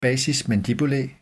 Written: basis mandibulae

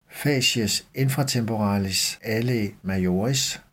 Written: facies infratemporalis alae majoris

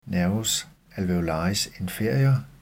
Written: n. alveolaris inferior

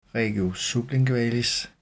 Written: regio sublingualis

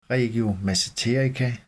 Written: regio masseterica

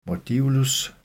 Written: modiolus

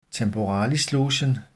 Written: temporalislogen

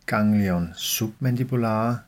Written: ggl. submandibulare

